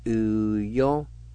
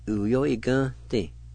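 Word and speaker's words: uyo igvnedi: Second part, let me mention this. The E with the N will be silent. You need to pronounce the N sound. And you can pronounce the N sound with the GV, like this. E-gun-ti. And put them together, it will sound like this. U-yo-e-gun-ti. U-yo-e-gun-ti. The N sound has a nasal sound to it.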